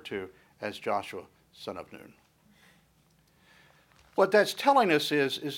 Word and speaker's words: Nun: Now, one of the things that we're going to, probably pronounced Noon,